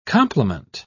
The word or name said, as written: compliment